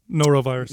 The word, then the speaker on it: norovirus: Yeah, that the Norton virus. – Norovirus. Norovirus. – Is that how you say it? – N-O-R-O, Yeah.